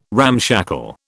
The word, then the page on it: ramshackle: British English